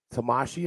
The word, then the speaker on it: Tamashii: Now, I know you've done some of the, and I'm going to butcher it and I apologize. I know you've worked some of the New Japan Tamashii events. – Yeah, perfect pronunciation.